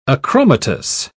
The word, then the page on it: achromatous: British English